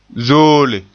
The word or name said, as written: zoole